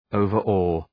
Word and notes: overawe: {,əʋvər’ɔ:}

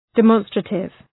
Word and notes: demonstrative: də’mɒnstrətıv

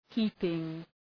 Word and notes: heaping: ‘hi:pıŋ

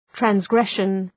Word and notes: transgression: træns’greʃən

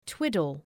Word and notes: twiddle: ‘twıdəl

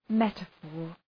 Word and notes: metaphor: ‘metə,fɔ:r

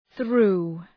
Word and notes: threw: ɵru:

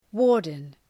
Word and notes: warden: ‘wɔ:rdən

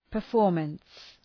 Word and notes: performance: pər’fɔ:rməns

